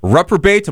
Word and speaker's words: reprobate: My Life is a Rock and Roll Reprobate. Am I saying that right, Lou? – Yep, absolutely. You nailed it.